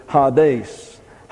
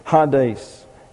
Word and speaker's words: Hades: H-A-I-D-E-S. Now this is an eta here, so it's like a long E, and it gets an A sound, so this is Hades. Hades. And you have a iota subscript here, and you transliterate it, but it's not pronounced. So we wind up with Hades.